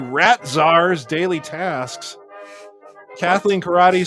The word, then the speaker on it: Corradi: Yes, but it's spelled C-O-R-R-A-D-I, but it is pronounced karate.